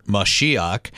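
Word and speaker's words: Mashiach (מָשִׁיחַ): If our listeners aren't aware, Messiah, or in good Hebrew pronunciation, Mashiach, means anointed one, just like Christ, or in Greek, Christos, means anointed one.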